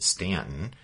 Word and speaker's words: Staunton: Stanton, and if you're looking on your map, it's spelled S-T-A-U-N-T-O-N, but pronounced Stanton,